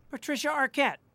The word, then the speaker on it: Patricia Arquette: I'm a big fan of Patricia Arquette.